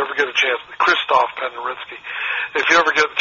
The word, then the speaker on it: Penderecki: It's spelled Penderecki, but I think they pronounce it Penderecki.